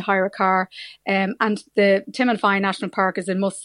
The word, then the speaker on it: Timanfaya: Well, tell us about the Timanfaya, if that's the way to pronounce it, National Park. – Yeah, you're spot on.